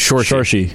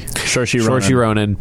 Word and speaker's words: Saoirse: And that's how you say it, I guess, is Saoirse. – Saoirse Ronan.